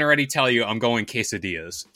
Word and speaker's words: quesadillas: No matter how many times I tell her, Mom, it's quesadillas.